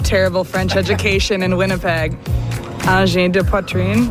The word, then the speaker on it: Angine de poitrine: Angine de poitrine. – Angine de poitrine.